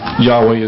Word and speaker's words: Yahweh: Now, I've understood from reading some, other material, in the Rotherham Bible specifically, and some other materials, that Yahweh is the correct pronunciation, and then somewhat different, but then Yahshua is Christ's name, which actually the name that God gave him, which glorifies God's name.